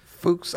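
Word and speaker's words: Fux: Fuchs, I think that's a safe pronunciation of that.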